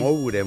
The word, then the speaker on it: Moure: Moure, muy bien. M-O-U-R-E, Moure.